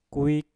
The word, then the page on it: kuik: Mpur